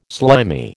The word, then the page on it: slimy: American English